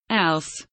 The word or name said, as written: else